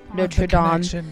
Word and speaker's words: Notre Dame: Which, by the way, I'm very proud of you for saying it right. – What? Notre Dame? – Yeah, not Notre Dame.